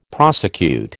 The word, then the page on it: prosecute: American English